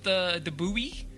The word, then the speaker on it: buoy: But I've been taking a certain amount of flack from the United Kingdom about the pronunciation of sensor buoys. – Boys. – Farmer decided to cut that baby in half and just go with the ridiculous boys. Canada also goes with the buoy.